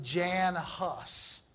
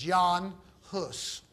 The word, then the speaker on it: Jan Hus: You might pronounce it Jan Hus. H-U-S-S. But it's Jan Hus. H-U-S-S.